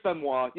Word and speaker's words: Benoit: It's Chris Benoit. Yeah, I said that right.